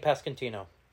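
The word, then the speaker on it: Pasquantino: Vinny Pascantino.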